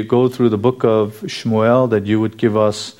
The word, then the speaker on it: Shmuel: And so we say Samuel and Sam, but there's really no A pronunciation in Shmuel.